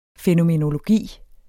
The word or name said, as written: fænomenologi